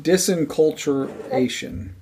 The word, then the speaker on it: disenculturation: Instead of deenculturalization? – Disenculturation. – Disenculturation. – Disenculturation. – Not disenculturalization. He said, what did he say? – I think he may have added a syllable.